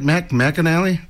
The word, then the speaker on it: Mac McAnally: We're going to start it out with Jimmy Buffett doing a Mac McAnally song. Did I say that right? Mac McAnally? Yes, there we go.